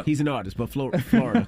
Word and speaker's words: Florida: Florida's three syllables.